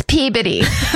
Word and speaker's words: Peabody: They go to IHOP in Peabody, Massachusetts, which I'm sure will have listeners that are like, it's Peabody or some fucking Massachusetts loves to not pronounce anything the way it looks. – Silent. It's just body.